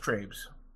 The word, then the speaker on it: Trabe: It's definitely pronounced Trabes.